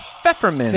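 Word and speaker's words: Pfefferminz: It's pfeffermins. – Pfeffermins. – Is how you pronounce it. Pfeffermins, the P at the beginning, the E in the middle, and the Z at the end.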